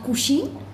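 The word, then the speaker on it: Kushiin: Nou, vandaag ben ik bij Abdi, bij een koffietentje eh, genaamd Kushin. Zeg ik het goed? – Ja, je zegt het helemaal goed.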